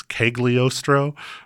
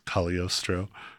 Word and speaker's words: Cagliostro: I had to pause there to make sure I said Cagliostro correctly because it's got a G in it. So it looks like it's Cagliostro, but I realize that the, like, fancy correct way to say it is Cagliostro.